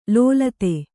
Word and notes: ಲೋಲತೆ: lōlate

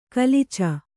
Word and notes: ಗಾಲೀಚ: gālīca

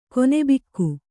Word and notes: ಕೊನೆಬಿಕ್ಕು: kone bikku

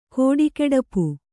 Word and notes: ಕಡುಗಡುಪು: kaḍugaḍupu

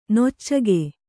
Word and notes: ನೊಚ್ಚಗೆ: noccage

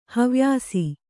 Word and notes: ಹವ್ಯಾಸ: havyāsa

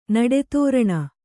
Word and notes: ನಡೆತೋರಣ: naḍe tōraṇa; Kannada

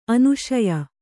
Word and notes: ಅನುಶಯ: anuśaya

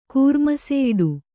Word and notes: ಕೂರ್ಮಸೆಯಿಡು: kannada

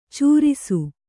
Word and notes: ಚಾರಿಸು: cārisu

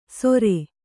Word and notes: ಸೊರೆ: Kannada

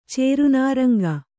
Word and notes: ಚೇರುನಾರಂಗ: cēru nāraŋga

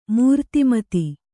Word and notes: ಮೂರ್ತಿಮತಿ: Kannada

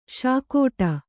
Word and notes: ಶೇಕಡಾ: śekaḍā

